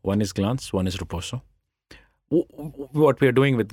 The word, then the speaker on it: Roposo: Then you bought a short video platform, Roposo. Is that how you pronounce it? – That's correct.